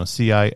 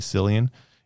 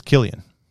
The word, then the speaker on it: Cillian: And a lot of people don't know that's how you pronounce his name. It's, you know, Cillian. A lot of people say Cillian. It's Cillian.